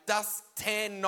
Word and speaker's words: Dustan: Because in the Bible, it's M-I-N, but she spelt my middle name M-E-N. Benjamin, not Benjamin. And so I say to my mum, okay, Dustan, not Dustin, I understand, but why am I Benjamin and not Benjamin?